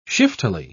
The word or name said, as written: shiftily